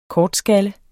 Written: kortskalle

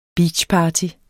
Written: beachparty

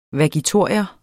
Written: vagitorium

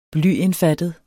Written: blyindfattet

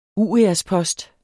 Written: uriaspost

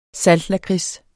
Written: saltlakrids

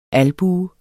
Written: albue

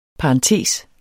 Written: parentes

